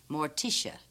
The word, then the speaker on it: Morticia: weirdly wonderful Morticia in The Addams Family.